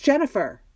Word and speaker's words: Jennifer: It's Jennifer.